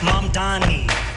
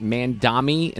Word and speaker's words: Mamdani: M-A-M-D-A-N-I. The name is Mom Donnie. – Fred tells me this is some sort of TikTok thing. – Learn how to say it. M-A-M-D-A-N-I. – I would say this, like, when he first won that primary, I think I was saying it Mandami instead of Mam.